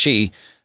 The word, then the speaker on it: Qi: Q-I pronounced chi.